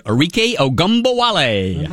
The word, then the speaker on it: Arike Ogunbowale: Arike Ogunbowale.